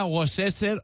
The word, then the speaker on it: Worcestershire: Pero es que yo sí lo leo, aquí hay un shire al final, Worcestershire. – Pero no se pronuncia.